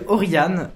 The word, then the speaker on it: Orion: Alors oui, ça s'appelle Oriane, qui s'écrit Orion.